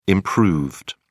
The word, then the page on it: improved: British English